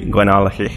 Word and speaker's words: Glenallachie: In Scottish, it is Glenallachie, but that's pretty hard to say, even for me. So we've just said to people to firm up that last CH, and Glenallachie is fine, because even then I can pronounce that.